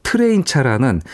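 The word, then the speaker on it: Trijntje: 아무래도 유럽인들은 이제 발음을 자국에서 음. 쓰는 거랑 영어 발음이 좀 다르긴 한데요. 음, 네. 이 아티스트 조금 깁니다. 근데 이제 우리가 통상적으로 영어식으로 이제 트레인차라고 얘기를 하고 어, 있습니다.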